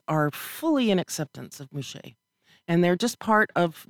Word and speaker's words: muxe: It's spelled M-U-X-E. – M-U-X-E-S. – E-S. Mouché. – Mouché.